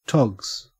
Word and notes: togs: Australian English

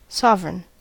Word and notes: sovereign: American English

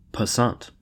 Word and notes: passant: American English

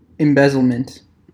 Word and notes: embezzlement: American English

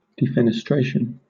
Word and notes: defenestration: British English

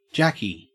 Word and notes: jacky: Australian English

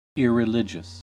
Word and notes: irreligious: American English

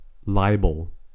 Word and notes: libel: American English

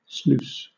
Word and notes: sluice: British English

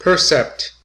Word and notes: percept: American English

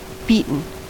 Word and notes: beaten: American English

